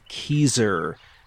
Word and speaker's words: Kezar: a lake in New Hampshire by the name of Keezer. That's spelled K-E-Z-A-R for those inclined to Google it.